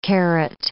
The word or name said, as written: caret